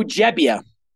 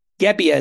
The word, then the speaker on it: Gebbia: Joe Gebbia, who people thought he's with a G. Gebbia is how people have pronounced it, but it's actually Gebbia.